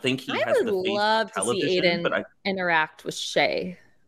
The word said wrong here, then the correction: Che: Or Che. – Shay Mitchell? – Che Diaz. I said Shay first.